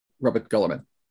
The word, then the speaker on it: Guilliman: So I'm still going by the official Graham McNeill uh, pronunciation of Robert Guilliman.